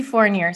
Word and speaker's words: Fournier: Chelsea Fournier. – See, I knew I was going to say it Canadian.